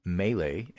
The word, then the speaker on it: Mele: So the opponent for the Republicans, who had an uncontested primary, is a guy named Greg, G-R-E-G-G, two Gs, Greg Mele, M-E-L-E, rhymes with Pele, I guess, who is a libertarian, occasionally Republican, corporate lawyer from Wall Street.